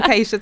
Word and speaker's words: Kaysha: So I'm Kaisha. So sorry, slight different pronunciation of the name. – Apologies. – There. No, it's fine. It was a good effort. So I'm Kaisha Thomas.